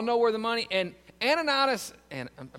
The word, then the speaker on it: Ananias: When I was a kid, everybody called them Annas and Sapphire. So I mispronounced their name for like 30 years. In Acts chapter 5, Ananias and Sapphira, we learned that Barnabas wrote that check.